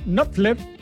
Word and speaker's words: Knopfler: Un 12 de agosto, se me había perdido la hojita, de 1949, nace Mark Knopfler en Glasgow, Escocia. ¿Está bien pronunciado, Miguel? ¿Usted que sabe mucho más de...? Sí, perfecto, ya.